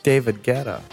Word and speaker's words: David Guetta: But is it David Goudier? – David Guetta? – Oh, David Guetta. – David Guetta? – David Guetta.